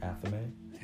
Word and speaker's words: Athame: Athame? – Yeah. – That's how you pronounce it? – Mm-hmm. – Gotcha. Athame.